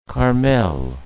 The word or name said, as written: Carmel